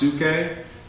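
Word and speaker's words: psuche (ψυχή): The Greek is, oh, I don't remember how to spell it, but it's pronounced psuche in Greek, but you can see from it we get psyche, you know, like psychiatrist.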